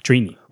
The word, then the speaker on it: Trini: Trini. Okay. The way it is, and that's what it sounds like every time it's Trini. Is that it's how it's spelled, too. – T-R-I-N-I.